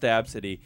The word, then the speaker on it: Abcde: She spells her name A-B-C-D-E, Absidy. – And it's pronounced Absidy.